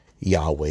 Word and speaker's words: Yahweh: Yahweh is Savior because it's connected to the name Yahweh. The old Protestant German pronunciation is Jehovah. You've heard of Jehovah Witnesses. It's basically the mistaken pronunciation, and it's stuck.